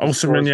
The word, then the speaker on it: Osimhen: I like that guy, Osimhen. That guy's I'm not even know if I'm saying it right, but that guy Osimhen, yeah.